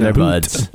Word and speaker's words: Bhoot: And it is Boot. B H O O T. Boot.